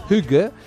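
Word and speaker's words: hygge: S'escriu H-Y-G-G-E i es diu Hücke. La recepta... – Crec que és més... No? Hücke.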